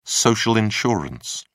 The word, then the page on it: social insurance: British English